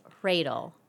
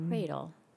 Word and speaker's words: Hradil: It's Hradil. So it kind of sounds like the H is silent, but an H sound is just almost like a breath. Mm. So it's Hradil.